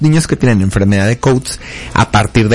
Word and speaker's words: Coats: Y la enfermedad de Coats, que se pronuncia Coats, que también es otra causa de leucocoria, son vasos anormales ahí adentro de la retina que generan sangrado, fibrosis, tracción.